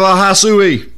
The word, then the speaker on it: Ahasuerus: Now it came to pass in the days of Ahasuerus. That's how you say his name.